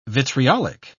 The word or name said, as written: vitriolic